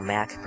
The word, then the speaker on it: Mack: and you write that out to Paul Mack Productions. My last name is spelled M-A-C-K,